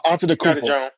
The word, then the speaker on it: Antetokounmpo: Antetokounmpo. I'm saying his name real wrong. – Giannis Antetokounmpo. – Giannis Yeah, you got it. – Onto the Koopa.